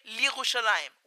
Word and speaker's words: Yerushalayim: Okay, do you pronounce the Yod, Li Yerushalayim, or do you say Li Yerushalayim without pronouncing the Yod, because the Yod becomes part of the preceding vowel, the Chirik, the E. And the school that we went with doesn't pronounce the Yod. So you say Li Yerushalayim, like that.